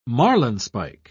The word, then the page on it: marlinspike: British English